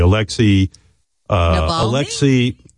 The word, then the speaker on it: Alexei Navalny: Alexei Navalny.